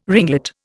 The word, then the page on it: ringlet: Indian